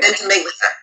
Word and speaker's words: Ventimiglia: played by Milo, I cannot say his name, Ventimiglia. You know, Milo. – Ventimiglia, I think, right? – Ventimiglia. Yeah.